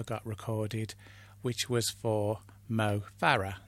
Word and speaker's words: Mo Farah: But it's only right to say his name correctly, so it's Mo Farah.